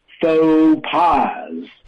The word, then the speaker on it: faux pas: So the first word is faux.Faux is the pronunciation, even though it looks like fox, it's not. Faux. And then the second word, P-A-S, pas. So it actually translates into English directly as false pass, right? False, F-A-L-S-E, P-A-S-S.